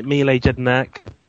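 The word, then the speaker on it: Jedinak: Mile Jednak. – Of course. Well done on the pronunciation there.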